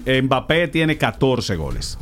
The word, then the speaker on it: Mbappé: Kylian Mbappé. Ahí. Con acento en la última E. – En acento en la E. – Sí, en la última. – No Mbappé, Mbappé como dice Soto.